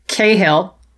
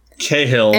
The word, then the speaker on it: Cahill: But also, that's not how you say it. But it's Cahill. – Cahill.